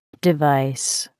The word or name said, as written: device